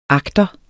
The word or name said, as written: agter